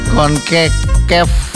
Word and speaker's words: Concacaf: Ini bacanya Concacaf. Concacaf. Victor Montagliani menjadi salah satu nama yang berpotensi maju sebagai calon presiden FIFA berikutnya.